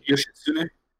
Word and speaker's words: Yoshitsune: I did get the strongest persona in Persona 4 after getting this Yoshitsune. I don't know if I'm butchering it. I'm probably Yoshitsune.